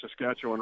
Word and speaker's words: Saskatchewan: So give it up for our Saskatchewan Roughriders.